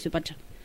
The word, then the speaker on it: Suipacha: De Suipacha. Lo dije bien, dije porque lo tenía anotado de otra manera.